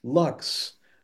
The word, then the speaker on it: Luxe: I can't remember where exactly, where you're like, yeah, it's Lux, like deluxe, but it has the E just for class.